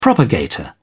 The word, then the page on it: propagator: British English